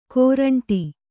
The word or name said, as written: ಕೋರಂಟಿ